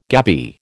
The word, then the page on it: gabby: American English